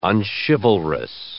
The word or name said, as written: unchivalrous